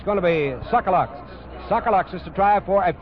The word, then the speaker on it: Tsagalakis: He spells his name T-S-A-G-A-L-A-K-I-S and pronounces it Sokoloxis.